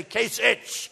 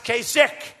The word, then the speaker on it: Kasich: And I don't know how you pronounce his name, Kasich. It's I-C-H. Every time I see it, I say Kasich, but it's pronounced Kasich.